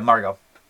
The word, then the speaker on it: Margaux: Yeah, oh, sorry, yeah, Margot. Yeah, it's spelled M-A-R-G-A-U-X.